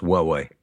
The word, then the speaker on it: Huawei: No, it's pronounced Huawei. – Huawei. With a H.